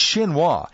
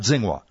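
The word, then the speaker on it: Xinhua (新华): It's Xinhua, not Xinhua.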